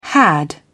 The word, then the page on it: had: British English